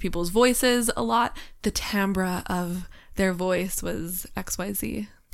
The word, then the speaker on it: timbre: I just want to make a quick call that not everyone may know Timbre is also a call to the music theory. – Absolutely. Timber. Uh, you- it's actually pronounced like timbre or something.